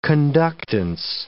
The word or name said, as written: conductance